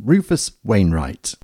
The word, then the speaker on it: Rufus Wainwright: And Devils and Angels by Rufus Wayne. I can't say his name properly. Rufus Wainwright.